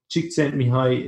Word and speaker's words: Csikszentmihalyi: Csikszentmihalyi, it's not spelled like that, but it's a huge surname. But Csikszentmihalyi is how you pronounce it.